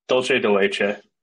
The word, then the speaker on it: Dulce de Leche: Dulce de Leche. It's Spanish.